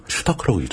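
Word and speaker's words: Stark: 어, 스타크는 독일어로도 단어가 있습니다. 스타크? 슈타크라고 읽죠, 슈타크. 슈타크.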